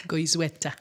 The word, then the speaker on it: Goizueta: Did I say that right? – Goizueta.